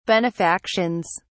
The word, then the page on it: benefactions: British English